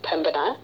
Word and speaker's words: Pembina: You bet, and you touched on one of the great mysteries about Pembina right off the bat is how do you pronounce it? It is Pembina.